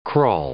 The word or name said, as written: crawl